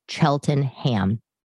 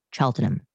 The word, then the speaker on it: Cheltenham: And it's spelled like Cheltenham, but they say Cheltenham.